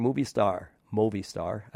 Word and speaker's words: Movistar: Speaking of not young anymore, but still exciting, Nairo Quintana, riding for Movistar, actually, is how they say it over there.